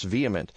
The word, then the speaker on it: VHEMT: It's the VHEMT, pronounced vehement.